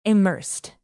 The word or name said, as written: immersed